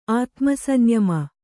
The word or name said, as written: ಆತ್ಮಸಂಯಮಿ